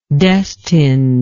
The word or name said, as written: destined